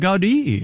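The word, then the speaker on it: Gaudí: Gaudi. – Yeah, that's how they pronounce it.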